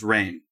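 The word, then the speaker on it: Rahne: Marvel Wiki says it's pronounced Rain.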